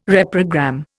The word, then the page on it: reprogram: Indian